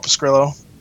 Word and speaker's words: Pescrillo: Anyways, You can find me on Twitter at Paul Pescrillo. My last name is spelled P-E-S-C-R-I-L-L-O.